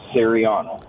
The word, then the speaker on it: Cieriana: C-I-E-R-I-A-N-A, Sariana. – Sariana.